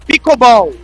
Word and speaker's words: pickleball: Campeonato de pickleball. – Ficou meio travante essa sonora. Vamos tentar de novo. 17 horas e 44 minutos. É, rapaz, teve aí o plic. Pickleball, é assim que fala, ó.